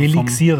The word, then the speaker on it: Elixir: Eben und, und wie schreibt man das, Elixir? E-L-I-X-I-R. – Also Elixier, vom, oder? – Ja, genau.